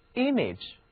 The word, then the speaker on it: image: I am 是表示像的词根， A-G-E age 名词后缀，字母 A 在以 age 为结尾的多音节名词或形容词中一般都读作短一。好，这两者组合起来就是 image，图像、肖像、印象。